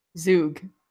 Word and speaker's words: Zug: What's the proper pronunciation? – Zug. – Zug. Yeah, we have those in syllables, man. You can do that. You can say Zug.